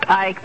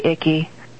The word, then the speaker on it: Icke: Well, it's pronounced Icke, but you always say Icky.